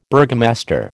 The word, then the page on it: burgomaster: American English